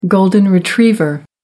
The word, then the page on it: golden retriever: American English